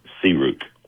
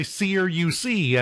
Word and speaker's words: CRUK: It's pronounced CRUK. – Okay, CRUK. – See, I was going to say CRUC, and that would have been totally wrong, but that makes more sense.